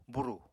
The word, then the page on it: burù: Apatani